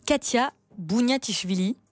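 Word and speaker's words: Khatia Buniatishvili: Alors, ils ont trouvé une artiste incroyable qui s'appelle Katia Bouniatichvili. Qui est-elle ? – Très bien prononcé.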